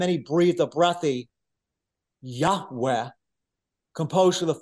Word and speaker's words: Yahweh: And so Yahweh, and this is the best that we are aware, according to our Semitic scholars, of how to pronounce his name, Yahweh, because in the Hebrew alphabet, we don't have vowels. There's only consonants.